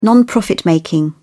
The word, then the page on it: non-profit-making: British English